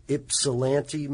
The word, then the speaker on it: Ypsilanti: In Ypsilanti, Michigan.